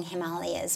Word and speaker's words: Himalayas: It's the correct way to say it. For those of you who have not yet been initiated into the Sanskrit pronunciation of things, people call it the Himalayas, of course. Actually, in India, they say the Himalayas in the same way that we say Ganga instead of the Ganges.